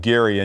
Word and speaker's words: Gerry: That his name was pronounced Gary, and yet we say gerrymandering.